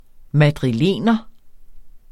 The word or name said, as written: madrilener